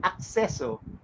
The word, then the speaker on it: Accesso: Well, I had to stop and then clear my mind that it's Accesso because it's access. Tama? – Yes, correct.